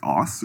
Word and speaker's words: Aass: But apparently the ass is pronounced differently. The double A is A-A-S-S. So it's ass with an extra A. But apparently that's pronounced like oss or something like that.